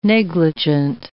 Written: negligent

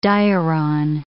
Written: diuron

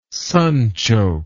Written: sunchoke

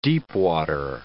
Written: deepwater

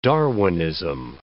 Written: Darwinism

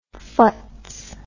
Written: phutz

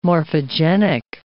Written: morphogenic

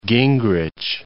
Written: Gingrich

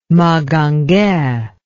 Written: magangue

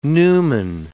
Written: numen

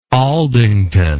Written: aldington

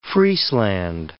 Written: Friesland

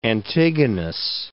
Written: Antigonus I

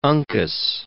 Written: Uncas

